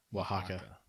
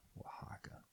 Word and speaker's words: Oaxaca: what was the pronunciation on it? – Oaxaca. – Oaxaca.